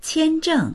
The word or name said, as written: qian1zheng4